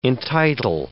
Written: entitle

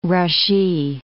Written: rushee

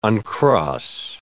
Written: uncross

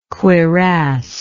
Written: cuirass

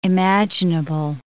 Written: imaginable

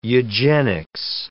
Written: eugenics

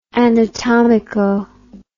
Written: anatomico

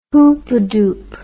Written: hoopadoop

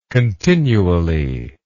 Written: continually

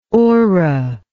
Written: orra

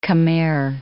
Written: Khmer

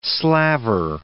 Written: slaver

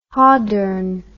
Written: hordern

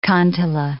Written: kantele

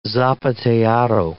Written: zapateado

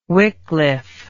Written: Wickliffe

